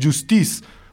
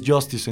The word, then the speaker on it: Justice: No, la banda de electrónica es Justice, porque es un nombre en francés, no es Justice en inglés.